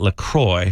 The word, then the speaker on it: LaCroix: Officially, they pronounce it LaCroix, so it rhymes with enjoy, not LaCroix or LaCroix. Yeah, anything French might say it like LaCroix.